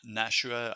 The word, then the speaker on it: Nashua: Is it Nashua, right? Is that how it's pronounced? Communication? – Yeah, Nashua.